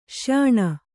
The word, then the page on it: ಶ್ಯಾಣ: śyāṇa